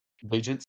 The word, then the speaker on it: Bagent: I feel like the backup, the rookie, what is it, Badgett? – Bagent, I think, is Bagent. How it's pronounced.